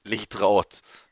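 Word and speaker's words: lehitraot (להתראות): Hebrew for goodbye is lehitraot.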